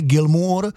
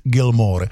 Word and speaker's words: Gilmour: To jméno je David Gilmour, jo? Ne Gilmour, někdo dokonce říká Gilmour, ale David Gilmour.